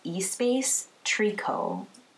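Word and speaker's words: Espace Tricot: And I completely butchered the name, but the name is actually pronounced Espace Trico.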